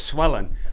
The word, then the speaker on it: Sue Ellen: My family pronounced it as Swellin'. Like it was never Sue Ellen is Swellin'.